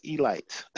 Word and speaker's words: Elite: Spelled elite, but it's pronounced Elite. That's important. Don't be a rookie. Pronounce it Elite, okay?